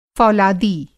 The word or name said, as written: फ़ौलादी